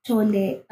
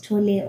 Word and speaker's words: Tolle: Yeah, and so this month's read for me was The Power of Now by Eckhart Tolle. I'm not sure if I'm supposed to pronounce it as Tolle or Toll. Tolle sounds better.